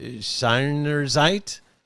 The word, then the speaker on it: Seinerzeit: There, uh, if I say it correctly, Almdorf Seinerzeit? Yes, yes, yes, very good.